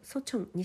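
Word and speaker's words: ソチ ョン (서촌): 先週もいくつか、ディナーやランチの約束があったんですが、とっても印象的だったのが、キョンボ君の近くのソチョン、西の村と書いてソチョンエリアですね。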